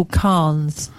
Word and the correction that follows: Kahan: K-H-A-N. – Yeah, it's K-A-H-A-N, yeah. So it's spelt quite a different way. – Kahan.